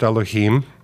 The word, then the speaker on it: Elohim: In Hebrew pronounced Elohim.